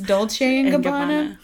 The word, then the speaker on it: Dolce and Gabbana: And I know that that is pronounced Dolce and Gabbana.